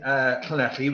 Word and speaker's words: Llanelli: Yeah, I've live in Llanelli, which you pronounced excellently.